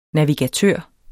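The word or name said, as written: NAVIGATØR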